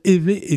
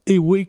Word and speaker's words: Éwé: Ça se prononce bien évé et non pas éwé, comme on l'entend fréquemment quand on parle de cette langue-là.